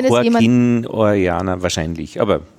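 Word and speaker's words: Joaquín: Joaquin, Joaquin Orellana. – Joaquin, also, Joaquin, Joaquin. Also der Akzent muss man es betonen, Joaquin, Ore, Joaquin Orellana.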